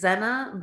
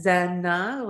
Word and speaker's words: Zeina: My name is Zeina Baltagi. And it's just said Zeina, like, you know, two syllables.